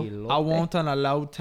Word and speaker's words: elote: So what I'm talking about is called, and I'm going to say this wrong, elote? – What? – That sounds right. – It's E-L-O-T-E. – It sounded good.